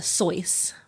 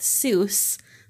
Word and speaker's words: Seuss: Um, he pronounces it uh, Sois, rhymes with rejoice. Um, but he decided to go with Seuss because it rhymes with goose, as in mother goose.